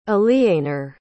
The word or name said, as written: aliénor